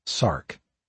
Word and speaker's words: src: The gene was called SRC, pronounced SARC, a diminutive of sarcoma.